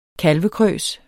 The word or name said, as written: kalvekrøs